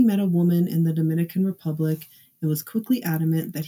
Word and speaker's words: Dominican Republic: Per the Charlie Project, on July 19th, Phillip returned home from a trip to the Dominican Republic. Remember, I mentioned earlier that he was very excited about this trip. – It is the Dominican Republic, you guys. She mispronounced that.